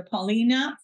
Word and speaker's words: Paulina: I'm on Instagram and you can find me under Paulina Fernandez MK. So Fernandez is spelled F-E-R-N-A-N-D-Z, and Paulina is P-A-U-P-A-U-L-I-N-A.